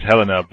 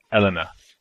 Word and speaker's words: Helena: Everyone says Helena, but her name's Elena. Elena. With that, it's like French for the H is silent.